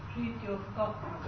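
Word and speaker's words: Fritjof Capra: Fritzov Capra.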